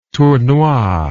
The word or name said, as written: tournois